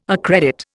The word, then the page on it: accredit: Indian